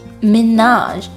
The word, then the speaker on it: Minaj: 浊化成 T 读坏了之后姓名的读音谐音就好像变成了抖森 Tom Hiddleston 麻辣鸡 Nicki Minaj Nicki 发音稍微简单一点而 Minaj 千万不要跟着中文昵称走哦正确的读法应该是 Minaj 最后的字母 J 发一个很轻很轻的 R 就可以了